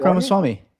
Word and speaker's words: Ramaswamy: Is it Vivek Ramaswamy? Vivek Ramaswamy.